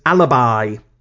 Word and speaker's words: Alabai: It's a Turkmen Shepherd dog known as Alibi. Take from that what you will. Not in Alibi as in your excuse for not being at the scene of a crime. It's spelt A-L-A-B-A-I.